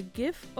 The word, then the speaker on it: GIF: Whether you say GIF or JIF, it's basically up to you. There is no clear argument for either pronunciation. Both are acceptable.